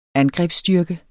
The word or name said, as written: angrebsstyrke